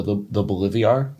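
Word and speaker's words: Bolívar: And then it seems like the goal is to replace their current currency, which is the Bolivar.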